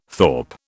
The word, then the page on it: thorp: British English